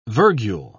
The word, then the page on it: virgule: British English